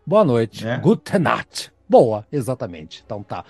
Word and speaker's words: Gute Nacht: Deve ser Gute Nacht, Nacht. É só você pensar Guten Morgen, Guten, não sei isso.